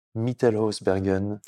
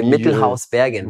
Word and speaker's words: Mittelhausbergen: Merci, de nous avoir reçu chez toi à Mittelhausbergen. – C'est bien, tu le prononces bien. c'est hein. pas mal, en allemand, ça fait Mittelhausbergen.